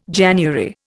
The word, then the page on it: January: Indian